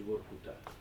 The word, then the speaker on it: Vorkuta: Poi dicono che si pronunci Vorkuta, c'è dibattito sulla questione.